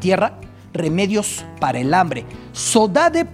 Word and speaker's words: saudade: Saudade, saudade, es un término portugués que así se pronuncia en el archipiélago.